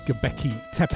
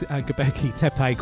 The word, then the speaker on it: Göbekli Tepe: And then we had Enver Goyken with Göbekli Tepe. I know how to pronounce that because I watch a few Graham Hancock videos. They're sort of historian, author, really interesting guy, and he mentions Göbekli Tepe quite a lot, and so that's why I know how to pronounce that.